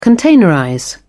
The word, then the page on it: containerize: British English